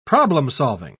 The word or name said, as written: problem-solving